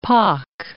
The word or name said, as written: pock